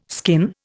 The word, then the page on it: skin: Indian